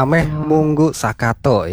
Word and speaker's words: Ameh Munggu Sakato: Banang begitu. Ameh Munggu Sakato ya. Begitu benar ya untuk pengucapannya ya.